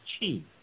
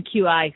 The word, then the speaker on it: qi: And by the way, QI, we've been pronouncing it wrong. We've been corrected by many listeners on Facebook who are saying it's pronounced chi, like C-H-I.